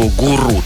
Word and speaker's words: гуру: Кстати, словарь говорит, что гуру, так что, если что, берите на вооружение.